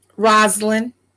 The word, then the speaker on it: Rosalind: Rosalind.